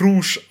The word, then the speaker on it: Rogue: Metal Rogue. – bei Metallic Rogue geht es um unsere namensgebende Rogue, würde ich jetzt sagen. Aber wie ich im deutschen Synchro mitbekommen habe, spricht man es Rouge aus.